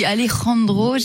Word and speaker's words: Alejandro: Oui, dites-le, mais moi aussi, Alejandro,